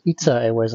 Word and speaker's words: ITA Airways: and which is one is now learned to pronounce it ITA Airways, not ITA.